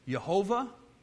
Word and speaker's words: Yahweh: And we actually aren't sure exactly how this name's pronounced. You know, we throw the A and the E in there, and we pronounce it Yahweh. It's also been pronounced as Jehovah, okay, because that actually...